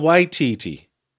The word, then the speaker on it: Waititi: First, Lisa Simpson says it, and she says Taika Waititi. – Mm-hmm. – with the emphasis on the first syllable. – Mm-hmm. – Because I've been saying Taika Waititi. – Right. – And I think later he says, he almost puts the emphasis on both, Taika Waititi.